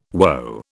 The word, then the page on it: WO: British English